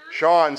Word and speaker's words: Sean: Stephanie gave birth to her baby boy, Sean Eric, which Sean, by the way, Sean, so that you know, Sean is, and it's spelled, it's got a funny spelling, it's S-E-A-N, that's the Irish spelling, Sean is the Irish word for John.